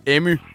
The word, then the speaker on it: Emmy: Udtales dit navn Emmy eller Emmy? – Emmy. – Så man siger det med y til sidst.